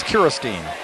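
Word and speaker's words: Kirasteen: That's K-I-R-A-S-T-E-E-N. I'm not mispronouncing. They do have a Kirsten on their team, but this one is Kiristeen.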